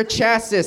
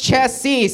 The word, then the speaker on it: chassis: Oh, baby, I want to get under your chassis, said John. It's pronounced chassis, said Christina.